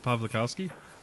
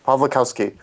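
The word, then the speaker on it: Pawlikowski: How do you pronounce the director's last name? – Pavlikovsky. – Pavlikovsky.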